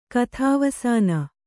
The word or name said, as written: ಕಥಾವಸಾನ